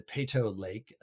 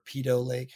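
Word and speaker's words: Peyto Lake: You run into Peto Lake again, or Peto Lake, however they pronounce it.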